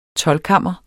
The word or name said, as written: TOLDKAMMER